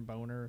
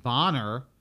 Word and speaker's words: Boner: And his name's, like, Boner, something about, like, Kevin Boner or something like that. – I would have pronounced it Bonner. – I wouldn't have. Oh, no. Boner. – Well, yeah. Oh, well, I mean. – Boner looks right.